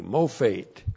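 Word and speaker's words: mophate (מופת): Looks like that in the Hebrew, M long O P H E T, mophate.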